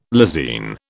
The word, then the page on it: lysine: British English